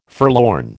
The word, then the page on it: forlorn: American English